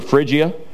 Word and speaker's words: Phrygia: He starts and he goes through, this is the Galatia area here, okay, and Phrygia, okay, the best way to remember Phrygia, how to pronounce that, it's frigid, okay, it's not really frigid there, but it helps me, okay.